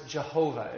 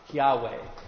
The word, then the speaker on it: Yahweh: So there's been in the old days, they used to pronounce it Jehovah, if you've ever heard that way of pronouncing it. Scholars today are pretty agreed that it's called, you would pronounce it Yahweh.